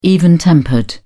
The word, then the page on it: even-tempered: British English